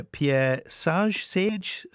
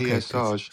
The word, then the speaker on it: Pierre Sage: Tell me about, now, am I going to pronounce his name properly here? Pierre Sage? – Yeah, I think you got it nailed on the first time. – Okay, Pierre Sage.